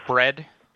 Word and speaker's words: Bred: Okay, so my name is Bred. – Spell it for me. – B-R-E-D.